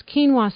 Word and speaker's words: quinoa: In the United States, the word spelled Q-U-I-N-O-A is pronounced quinoa.